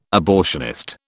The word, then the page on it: abortionist: British English